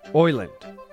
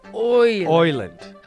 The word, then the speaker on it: island: A true islander pronounces island Oiland, which I think is spelled O-I-S-L-A-N-D, so Oiland.